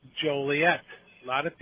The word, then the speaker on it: Joliet: Your hometown, by the way, for people who might not know, Joliet.